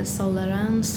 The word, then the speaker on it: Sollerön: In Swedish, it's Sollerön.